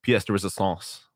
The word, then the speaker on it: pièce de résistance: I mean, I feel like the reality of it is that hockey is his pièce de résistance.